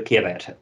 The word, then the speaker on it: caveat: The Bullet Train is a recommendation with a caveat, which is, I don't know if I can pronounce that properly, but... – Caveat, that's the one, yeah, with a caveat.